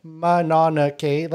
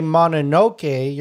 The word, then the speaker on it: Mononoke: Yeah, from what I know of like Japanese anime, because I used to watch a lot when I was younger, usually their pronunciation is very stuttered. Like each syllable gets like equal relevance. So I think Mononoke is a little bit more of the pronunciation.